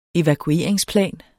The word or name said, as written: evakueringsplan